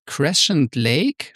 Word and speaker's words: Crescent Lake: Genau, es geht um den Crescent Lake, spricht man das so aus, in Dunhuang. Ja, genau.